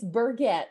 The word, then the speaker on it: Burget: It's Burget.